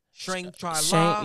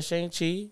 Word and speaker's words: Shang-Chi: Shang-Chi, Shang-Chi-La. – Shang-Chi.